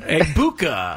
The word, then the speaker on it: Egbuka: How do you pronounce his name? – Egbuka. – Egbuka. Okay. – Egbuka. Just like it looks, actually.